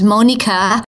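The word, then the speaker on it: Monika: So the best place to go is my website, which is Monica, spelled with a K, M-O-N-I-K-A, and Zands, Z-A-N-D-S, which is my last name, .com.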